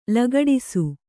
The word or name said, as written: ಲಗಡಿಸು